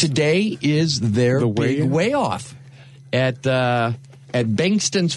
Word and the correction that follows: Bankson's: Farm. It's actually Bankson's.